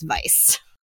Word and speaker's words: Weisz: And then that led me to saying, I thought it was Rachel Weisz or Weisz or something to that. I've heard it pronounced many ways, and I have never once said it with confidence. And so that led me down a rabbit hole of watching YouTube videos of her and others correctly say her name, and apparently it's Weisz.